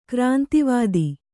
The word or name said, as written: ಕ್ರಾಂತಿವಾದಿ